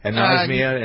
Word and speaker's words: anosmia: The pandemic also has put insomnia. Is that how you pronounce it? Anosmia, insomnia. What is that? It's a loss of smell. – Yeah, it's anosmia.